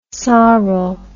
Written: sorel

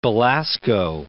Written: Belasco

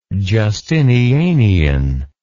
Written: Justinianian

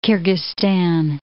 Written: Kyrgyzstan

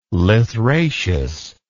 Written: lythraceous

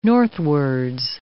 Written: northwards

